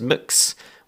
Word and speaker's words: Mx: And just to go back to what Helen mentioned, which is mix, which is spelled MX, as we said before.